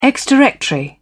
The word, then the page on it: ex-directory: British English